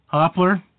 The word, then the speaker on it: Hopler: My guest tonight is Tammy Hopler. – No. Hopler.